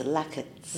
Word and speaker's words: Lakits: I have heard Laksit, Latsis, Lakits. How's the pronunciation, please? – It's Lakits.